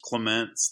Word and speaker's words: Clemence: I can't remember if you said it right. It's just Clemence. That's it.